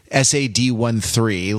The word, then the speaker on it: Sad13: So I'm going to go with Sadie Dupuis, who has released a solo album under the name Sad 13, SAD13, like sort of text speak for Sadie, but it's, as she makes clear, it's pronounced Sad 13.